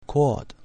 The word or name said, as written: quod